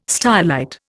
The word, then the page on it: stylite: Indian